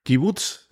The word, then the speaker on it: kibbutz: Kibbutz.